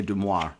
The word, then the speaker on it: Du Maurier: I'd call that Daphne Du Maurier. – There you go. Du Maurier.